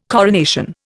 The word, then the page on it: coronation: Indian